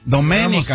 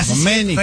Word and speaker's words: Domenica: Domenica, Antonio, Domenica, con acento en la E, Antonio. – Ah, Domenica.